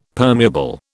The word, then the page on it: permeable: British English